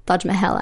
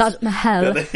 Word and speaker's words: Taj Mahal: Taj Mahal, actually, is what it's called. – Pronounce it correctly, of course. – Taj Mahal.